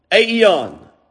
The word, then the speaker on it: aion: Here in the world, you look it up in your concordance when you get home, Strong's concordance, and it will say that the Greek word is A-I-O-N, or aeon, aeon is the way that is pronounced, or aeon, aeon, aeon.